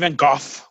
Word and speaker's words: Van Gogh: They say it's Vincent Van Gogh with a soft H at the end. But that's the Dutch pronunciation. He was Dutch, but yeah, living in France.